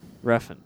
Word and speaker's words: Refn: Redfern. – Yeah. – Whatever. Fuck you. What's his actual name? – Nicholas Winding Refn. – Refn, right. Nicholas Winding Refn.